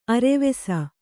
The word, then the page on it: ಅರ್ವಿಸು: Kannada